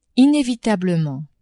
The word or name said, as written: inevitablement